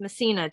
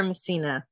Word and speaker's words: Messina: Last name's pronounced Messina, Jennifer Messina.